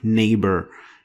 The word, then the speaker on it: neighbor: It was basically changed to F or nothing, like neighbor. You don't pronounce the GH.